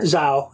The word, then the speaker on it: Zhao: Zhao. I think it's just Zhao.